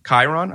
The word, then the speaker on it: Chiron: Is it, how do you say Jonathan Rhys? Chiron's character? – Chiron, okay. – I think it's Chiron.